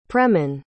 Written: preman

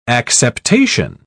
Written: acceptation

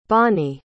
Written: Bonney

